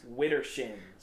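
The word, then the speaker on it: Widdershins: Wittershins. – Wittershins. Wintershins. – No, it's Wittershins. – He's just describing. – You know how people in Lancaster say water? Witter. – Yeah. So Wittershins is like your shins in the wintertime.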